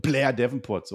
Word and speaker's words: Blair Davenport: Weil du über Blair Davenport redest, man muss das so aussprechen. Blair Davenport, so heißt sie nämlich.